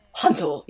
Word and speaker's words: Hanzo: And Hanzo. Hanzo. You have to say his name like that.